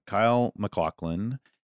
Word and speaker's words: Kyle MacLachlan: And Kyle MacLachlan plays that.